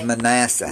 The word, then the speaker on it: Manasseh: And that's Manasseh. Is I say that right? – This was Manasseh.